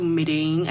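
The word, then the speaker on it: Medellín: And then she went to Medellin. I think that's how you pronounce it. – Medellin. – Okay. – Both work.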